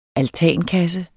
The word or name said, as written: altankasse